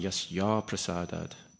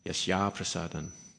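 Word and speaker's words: yasyāprasādān: Because I sung yasya prasadad rather than yasya prasadan.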